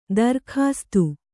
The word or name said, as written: ದರ್ಕಾಸ್ತ್